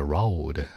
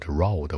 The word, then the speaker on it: road: 但说句实话哈，我我我我在开高速的时候没有没有这么样用过，所以在路上叫 on the road 或者 on the way，注意发音，road road 不是肉的啊，还菜的呢，把嘴巴撅起来点，我我 road。